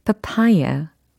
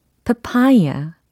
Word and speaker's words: papaya: 파파야는 영어로 papaya, papaya 이렇게 발음해 주시면 되겠습니다. 파파야 이게 아니라 papaya 이렇게 papaya 아 이음절에 강세가 있구나 아시면 되겠어요. 그리고 끝 부분의 모음이 파파야 아니고 파파야 아니고 파파야 papaya. 약간 이어 이렇게 끝난다라는 겁니다.